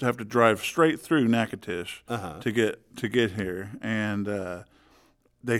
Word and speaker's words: Natchitoches: Natchitoches, Louisiana. Which is not spelled like Natchitoches at all.